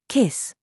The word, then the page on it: SAUCE: British English